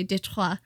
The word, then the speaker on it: Detroit: He kind of led this charge against Fort Detroit, or back then it would have been pronounced in the French way, Detroit.